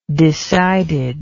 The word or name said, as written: decidedness